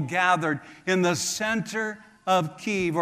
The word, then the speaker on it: Kyiv: I was in Kyiv, or you may say Kiev. One is the pronunciation of Russian, and the other one is in Ukrainian.